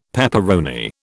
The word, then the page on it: peperoni: British English